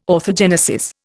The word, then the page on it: orthogenesis: Indian